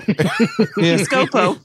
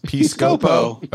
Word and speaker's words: Piscopo: Piscopo. – Piscopo.